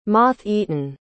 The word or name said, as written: moth-eaten